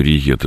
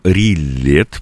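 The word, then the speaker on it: rillettes: Э, то есть э, публика судорожно думает о том, что же это вот такой риет, это паштет или что-то еще. Но ну, некоторые люди называют его словом рилет, потому что э, риет пишется двойное «л» между гласными, в французский читается как «и» краткое.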